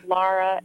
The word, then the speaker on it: Lara: So it's Lara, L-A-R-A, and Herscovitch is H-E-R-S-C-O-V-I-T-C-H.